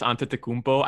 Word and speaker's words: Antetokounmpo: No, just the way he says Giannis Antetokounmpo. I guess he's Greek, so he pronounces it like Giannis. – And he says it with a D, right?